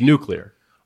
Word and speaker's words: nuclear: I have a thing where the word nuclear. I just did it. I know there's two ways to pronounce it. One is wrong, and every time I say it, it doesn't sound right. But it's nuclear.